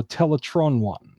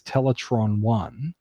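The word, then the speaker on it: Teletraan: So Optimus Prime pronounces the computer's name on the arc as Teletron 1. – Wow. – And Bumblebee pronounces it as Teletran 1.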